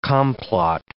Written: complot

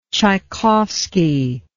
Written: Chaikovski

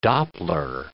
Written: Doppler

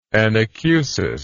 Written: anacusis

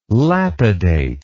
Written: lapidate

